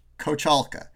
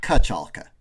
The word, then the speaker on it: Kochalka: I think if I say, if I'm formally saying how to pronounce it, I'd say Kochalka. But I don't really say the ko. I say more like ka. So I say like Kochalka.